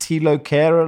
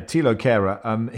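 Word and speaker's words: Thilo Kehrer: Is it Tilo Kehrer, Lars, the new signing? – Yes, that is it. – Yeah, Tilo Kehrer. He says, I've been calling him Theo, says David Moyes.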